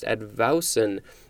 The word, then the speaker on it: advowson: Next is advousen. I believe that is how it's pronounced, advousen, A-D-V-O-W-S-O-N.